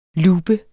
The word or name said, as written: LOOPER